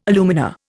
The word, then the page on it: alumina: Indian